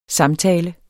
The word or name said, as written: samtale